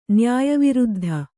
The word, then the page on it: ನ್ಯಾಯವಿರುದ್ಧ: nyāya viruddha